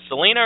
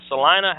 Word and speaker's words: Salina: Is it Salina or is it Salina? How do you say that? – Well, it depends on where you live. If you live in Kansas, it's Salina. Outside of Kansas, it's Salina. So,